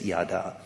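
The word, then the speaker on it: yada (ידע): The verb to know is a Hebrew verb yada. It is pronounced as yada.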